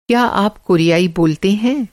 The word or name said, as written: कोरियाई